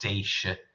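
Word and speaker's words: Déise: So in its modern form, it's spelled D-E-F-A-D-I-S-E, and it's pronounced Deisha. And I think this older spelling, D-E for the I-S-I, would be pronounced similarly.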